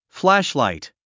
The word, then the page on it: flashlight: flǽʃlàit; フラッシュライト